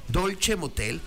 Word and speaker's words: Dolce Motel: Dolce Motel se pronuncia, pero se escribe Dolce con C, Motel.